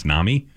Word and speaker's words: NAMI: I'm hanging out here with Kate and Martha from the National Alliance on Mental Illness, which I learned today is pronounced NAMI.